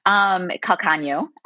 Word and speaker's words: Calcaño: a Calcango hand pie. – You got pretty close. Calcaño.